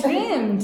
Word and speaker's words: dreamt: So American, dreamed. – Yeah. – Brits, dreamt. – Yeah. – Dreamed, dreamt. Dreamed, dreamt. – Have you ever dreamt of becoming a millionaire? – I have. – So have I. Yeah, so we just pronounce it. We don't say dreamed, we say dreamt.